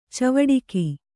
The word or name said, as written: ಚವಡಿಕಿ